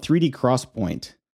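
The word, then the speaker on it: 3D XPoint: Yeah, it's called 3D Crosspoint, mm-hmm. even though it's spelled X-Point.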